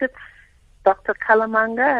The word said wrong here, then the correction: Nkalanga: am I saying his name right? – Nkalanga, yes. – Nkalanga, my apologies.